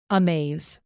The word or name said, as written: amaze